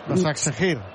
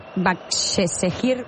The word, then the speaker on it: Başakşehir: Başakşehir. – Başakşehir, sí, ho dius bé.